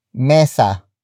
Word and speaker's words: mesa: And that is mesa. mesa mesa mesa is spelled as m e s a